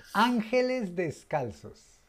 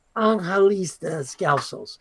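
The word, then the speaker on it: Ángeles Descalzos: How do you say it in Spanish? Correct me, please. – Angelis Descalzos. – Angelis Descalzos. – Yes.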